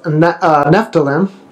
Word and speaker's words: Naphtali: Is it Naphtalim? – Naphtali. – Naphtali, you think it is? The M silent? – Naphtali? Well, the Old Testament is Naphtali.